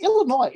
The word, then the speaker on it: Illinois: Is it Illinois? Is that S silent? – I think the S is silent like the L in salmon.